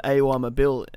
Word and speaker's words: Awa Mabil: But I know we've also got Awa Mabil, the forgotten one, Awa Mabil, Midtjylland in... – good pronunciation. I think you got it right.